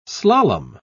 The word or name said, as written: slalom